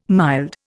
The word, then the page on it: mild: Indian